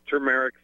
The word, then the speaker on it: turmeric: This turmeric thing is killing me because everybody out there is selling turmeric, but nobody knows how to pronounce it. They keep saying, like, it's T-U-M. It's T-U-R-M-E-R-I-C, turmeric.